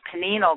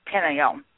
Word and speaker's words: pineal: pineal gland. – Pineal. – And right. Pineal. Pineal. Pineal. Like pinnacle, pineal.